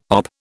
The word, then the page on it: OP: British English